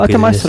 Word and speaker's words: Атамас: Я с фамилией не ошибся с ударением? – А, Атамас работает. – А, окей, Атамас. Хорошо, работает.